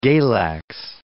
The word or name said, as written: galax